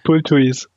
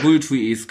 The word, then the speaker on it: Bulthuis: dann nach einem Kopfball von eurem Bulthus oder Bulthus, wie das ausgesprochen wird. – Bulthuis. – Bulthuis, genau, den